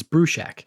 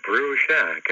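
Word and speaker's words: Bruchac: It's Brushak.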